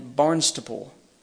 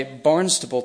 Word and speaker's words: Barnstaple: So I'm going to try my best to say Barnstable tonight, but you may hear Barnstaple. And in my defense, phonetically, that's what it looks like.